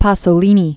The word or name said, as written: Pier Paolo Pasolini